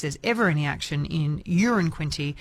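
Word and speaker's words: Uranquinty: It was Tambourine Bay Road, and I love this because Peter's texted in saying, I live in Urin Quinty. It's the same pronunciation as a number one, so it's not a big town. One could describe it as a wee place, and then a laughing emoji.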